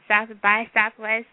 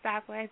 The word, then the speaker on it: South by Southwest: And we did the, had a chance to stop through South by Southwest because, and I said it right, it's South by Southwest. Last show I was like, Southwest. But it's South by Southwest.